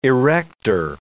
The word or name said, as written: erector